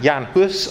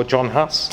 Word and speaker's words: Jan Hus: And there are two men I think are worthy of special mention, John Wycliffe and Jan Hus, or John Huss, as we would know in the anglicised way of pronunciation.